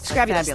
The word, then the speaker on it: Scrabulous: Scrabulous, thank you. You're right, it is Scrabulous. I just can't pronounce it.